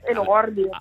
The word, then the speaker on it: Elordi: Beh, Jacob, com'è che si pronuncia? Elordi? O Elordi, insomma, quel che... – Elordi, sì.